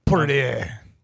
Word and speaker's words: Poirier: UFC 257, McGregor versus Poirier. Hope I said that right. – Poirier.